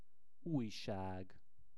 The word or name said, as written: ujsag